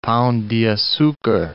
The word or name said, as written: Pao de Acucar